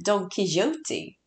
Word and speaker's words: Don Quixote: Ja, alltså i England säger de typ så här, Don Quixote.